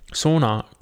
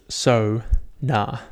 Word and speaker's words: sauna: Sauna, correctly pronounced so-na. So sauna. It's sauna. Sauna.